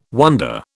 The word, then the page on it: wonder: British English